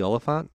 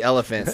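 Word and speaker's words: Olyphant: You don't have to pronounce it all French. I think it's just Timothy Elephant. – It's not.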